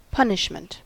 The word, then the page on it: punishment: ['pʌn.ɪʃ.mənt]; American English